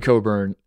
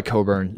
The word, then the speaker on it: Cockburn: Kofi Coburn. Mm-hmm. His last name is spelled like Cockburn. – Yes, like the director in Tropic Thunder. – And he goes by Coburn.